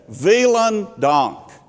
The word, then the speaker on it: vielen Dank: And I looked at her, and I said, vielen Dank. And she smiled, and I said, did I say it correctly? And she said, absolutely, bitte schön, which is, you're welcome.